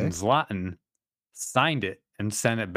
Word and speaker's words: Zlatan: Z-L-A-T-A-N? – Yeah.